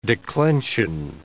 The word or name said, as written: declension